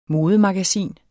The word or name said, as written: modemagasin